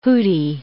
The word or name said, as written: hooty